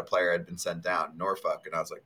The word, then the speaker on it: Norfolk: Also, how do you say it? N-O-R-F-O-L-K? – Norfolk. – Norfolk. Norfolk. So you basically say fuck when you say the city.